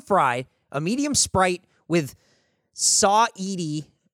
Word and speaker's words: Saweetie: I still feel like you're saying it wrong. – Yeah. I mean, that's just because you're looking at me maybe and going, there's no way he's saying it right. – Maybe it's Saweetie. – Oh, it is.